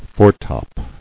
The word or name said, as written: Foretop